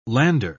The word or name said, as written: lander